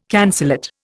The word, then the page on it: cancellate: Indian